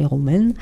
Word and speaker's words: Romeyn: Donc les Romaines, j'espère que je le prononce bien, hein, ça s'écrit R-O-M-E-Y-N, voilà, donc je dis les Romaines, étaient donc les héritiers, comme je le dis, de la manufacture, mais aujourd'hui, ils n'ont pratiquement plus rien.